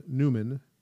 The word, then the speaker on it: Numan: By then, he was using the name Newman, the way it's spelled, N-U-M-A-N, and the band was performing live in pubs and clubs around England, supporting the Lurkers.